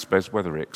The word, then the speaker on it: Beswetherick: And my name's difficult to pronounce, Beswetherick, so I'm very aware of people have trouble with my name a lot, so I feel for Mephibosheth.